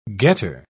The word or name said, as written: getter